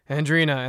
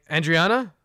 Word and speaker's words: Andrina: Uh, my wife her name is Andrina, but the way it's spelled, it looks like it says Andriana. So every time, she, she's like every fucking time she has to say Andrina, and they're like Andriana.